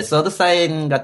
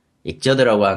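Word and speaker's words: Xrd Sign: 서드 사인 같은 익저드라고 하는데 서드 사인이라고 읽는데 나는 XRD니까 네.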